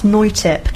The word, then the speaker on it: Noithip: It's a bit of a weird one, so it's Thai, so it's pronounced Noitip, but it's spelled with an H, like Noithip.